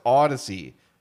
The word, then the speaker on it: Audacy: What is it? Yeah. Odyssey? No, it's Odyssey. – Yeah. – Oh, how do I spell that? A-U-D-A-C-Y. Good.